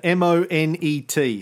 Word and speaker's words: Monet: There's a famous painter, M O N E T.